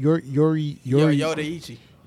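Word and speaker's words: Yoruichi: Number two would be Yoriuchi from Bleach. – Damn. Forgot about Bleach. – Oh, damn. Yo, the first time. I hope I. Did I pronounce that right? – Yoriuchi.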